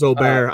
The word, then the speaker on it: Åberg: It's Åberg. I was shocked. It took me a hot minute to figure out who they were talking about. I went, oh my God, it's Åberg.